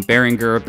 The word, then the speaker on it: Behringer: Say what you will about this pretty divisive company, Behringer, that's apparently the actual pronunciation as I just learned today.